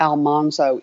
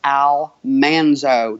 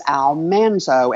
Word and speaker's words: Almanzo: It is actually not Almanzo. It is Almanzo. – Really? – Yes. Because that's why she called him Man Lee. The correct pronunciation of his name is Almanzo, and they did not research that before they did the show, and they pronounced his name wrong through the whole series.